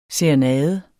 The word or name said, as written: SERENADER